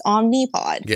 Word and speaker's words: Omnipod: But then I look at it and I'm like, no, it's got an I. It's spelled with an I. So it's Omnipod.